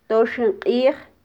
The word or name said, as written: toxin qij